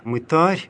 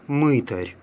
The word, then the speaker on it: мытарь: Отметим, что слово «мытарь», которое имеет значение «сборщик налогов», в церковнославянском языке произносится с ударением на «а», «мытарь», оно в русском языке обычно «мытарь».